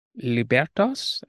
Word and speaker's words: libertas: Now, another aspect of the book, or a notion that you develop in the book, is that of libertas. I think I'm pronouncing it right. – Yes.